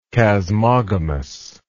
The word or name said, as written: chasmogamous